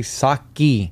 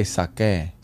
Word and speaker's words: sake: We did the TikTok on the sake, but we need to correct ourselves because a lot of people telling us that you don't say it like that. You don't say sake. – Okay. – Say sake.